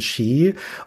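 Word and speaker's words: Sidhe: Ski spricht man das aus. – Ski spricht man das aus? – Ja, das ist ja ein Originalname aus der keltischen Mythologie.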